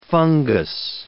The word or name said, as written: fungus